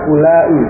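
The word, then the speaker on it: ulai (أولاء): Isim isyarah untuk jamak itu sama antara muzakkar dengan muannas, yakni lafaznya ulai. Ya, ulai. Ya, di situ tertulis ada wawu sesudah hamzah. Wawunya itu tidak diucapkan.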